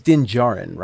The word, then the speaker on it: Din Djarin: And his name is Din Djarin, right? Am I saying that right? – Mm-hmm. Right.